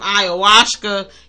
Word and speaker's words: ayahuasca: But I said that right. – Ayahuasca. Yeah, no, you said it right.